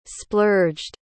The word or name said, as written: splurged